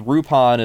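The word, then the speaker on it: Rupan: which Rupin is just the Romaji pronunciation of Lupin. It's R-U-P-A-N. It's how they pronounce Lupin in Japanese, but they typically in the U.S.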